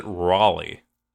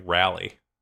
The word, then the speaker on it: Raleigh: Well, they pronounce it Raleigh, not like rally.